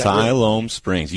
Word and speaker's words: Siloam Springs: Siloam Springs.